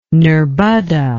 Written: narbada

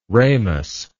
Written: ramous

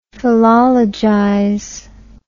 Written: philologize

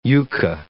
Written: yuca

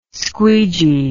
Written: squeegee

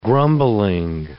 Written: grumbling

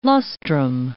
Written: lustrum

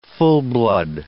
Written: full-blood

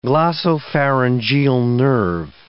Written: glossopharyngeal nerve

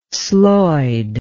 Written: sloyd

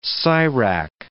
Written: Sirach

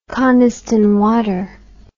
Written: Coniston Water

